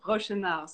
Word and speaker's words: Rochenau: It's Rochenau, so you just don't pronounce the D at the end.